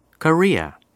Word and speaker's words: Korea: 첫 번째 단어, 와, 우리나라, 대한민국, 한국을 영어로 Korea. – Korea라고 해요. Korea, 읽을 때요, 코를 강하게 읽지 말고 re를 강하게 읽어주세요. 그래서 Korea, Korea 이렇게 발음하면 되고요.